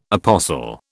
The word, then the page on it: apostle: British English